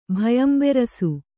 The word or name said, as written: ಭಯಂಬೆರಸು